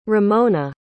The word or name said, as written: rimona